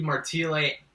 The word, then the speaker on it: Martile: Yeah, so it's Steve Martile, M A R T I L E.